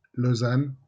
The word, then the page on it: Lausanne: [lɔzan]; French